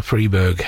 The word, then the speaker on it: Freberg: Stanley Victor Freberg, it's actually spelled F-R-I-B-E-R-G, but people would call him Freiberg, so he changed the spelling of it so people pronounced his name properly.